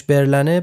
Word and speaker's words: برلن: اینکه چرا اسمش برلنه به خاطر اینکه سفارت آلمان درست نبشه این کوچه و خیابون فردوسیه و برلن هم البته تلفظ فرانسوی اسم پایتخت آلمانه.